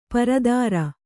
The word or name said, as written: ಪರಿದರ